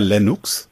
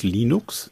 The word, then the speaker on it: Linux: Warum sagen Sie immer Linux? Ich habe gelernt, das heißt Linux. – Vielleicht habe ich es einfach fränkisch ausgesprochen. Ich sage eigentlich schon Linux.